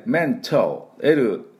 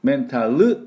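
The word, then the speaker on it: mental: 面タルと言わないように、ここがかっこよく出すポイントです。では私の後について発音いきましょう。メントメント、はい、で最後 L ね、メント L、あのメンタルというふうに最後 U をつけないね。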